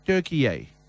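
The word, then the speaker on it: Türkiye: But the commentators, particularly the Australian commentators, did not call their opponents Turkey because they are from Turkey. No, because it's spelt in their own language. – Turkey A. – And that's what they call them, yeah. Turkey A.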